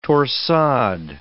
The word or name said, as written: torsade